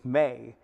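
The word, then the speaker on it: may (μή): It's pronounced may in the Greek language.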